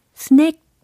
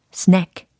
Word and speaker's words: snack: 아, 스낵. 이게 아니고 스낵. 이렇게 세련되게 발음 연습을 해주셔야 되겠죠. 스낵 이것과 snack, 이것의 차이는 한 끗 차이일 수도 있지만 굉장히 큰 차이입니다.